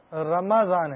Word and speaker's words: رمضان: مجھے دنیا میں بہت بڑا انعام ملا اور اس بولا جاتا ہے لفظ رمضان نہیں ہے, رمضان ہے.